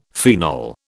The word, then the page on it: phenol: British English